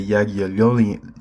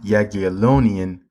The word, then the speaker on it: Jagiellonian: So it's pronounced the Jagiellonian, Jagiellonian